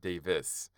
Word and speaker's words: Davies: Like, it's not supposed to be, it's spelled Davies, but it's really supposed to be pronounced Davis.